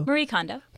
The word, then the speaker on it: Marie Kondo: Marie Kondo.